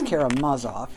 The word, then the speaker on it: Karamazov: and I asked my Russian neighbor, how do you pronounce that? She's Karamazov, in case anybody doesn't like my pronunciation.